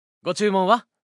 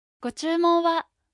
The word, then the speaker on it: ご注文は: ご注文は Гочумуа означает «что бы вы хотели».